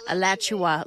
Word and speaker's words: Alachua: Alachua, like Joshua, but Alachua.